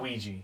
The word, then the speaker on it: Ouija: It's not Ouija. – It is Ouija. It's like Ouija Luigi, board. But Ouija. – Yeah, Ouija. That's how you say Ouija board.